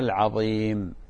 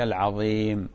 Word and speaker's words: العظيم: عمر من بريطانيا بارك الله فيك أخي الفاضل، العظيم قصدك حرف الضاء، حرف الضاء ينطق هكذا سبحان ربي العظيم، سبحان ربي العظيم، فالضاء غير الضاء، ها تنتبه لهذا، ولذلك تظهر الحرف هذا بشكل واضح.